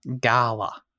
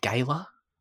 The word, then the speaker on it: gala: And the synopsis says, Debbie Ocean gathers an all-female crew to attempt an impossible heist at New York City's yearly Met Gala. I really wanted to say it right because they made a point of that in the movie as well. It's not gala. It's gala.